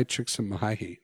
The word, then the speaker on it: Csikszentmihalyi: Mihai Csikszentmihalyi.